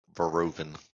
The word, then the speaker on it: Verhoeven: That's pronounced Verhoeven. – Verhoeven? Did you really? Damn. – Suck on that bitch. – What? So how do you know this? – Because the H is silent in that.